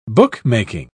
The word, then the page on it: bookmaking: British English